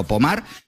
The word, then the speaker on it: Pomar: Se escribe Pomar, como si fuera en castellano, pero Pomar, pues allí en Francia, hablamos de unas imágenes.